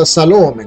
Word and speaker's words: Salome: Un confronto fra due versetti dei Vangeli, Matteo 27,56 e Marco 15,40, suggeriscono che l'unica persona chiamata Salome nella Bibbia, con l'accento sulla penultima sillaba, era la madre dei figli di Zebedeo, gli apostoli Giacomo e Giovanni.